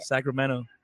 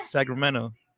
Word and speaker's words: Sacramento: Sacramento. – Oh, see, you didn't say your T. – So it sounds more like a D. – Yeah. – Sacramento.